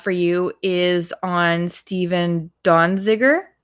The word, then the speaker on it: Donziger: And I do believe in the original episode, I do mispronounce the name as Donziger, but there's no N, so it's Donziger.